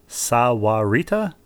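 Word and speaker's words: Sahuarita: And Rebecca, you pronounced her hometown correctly, Sawarita. I can't do it as well as you.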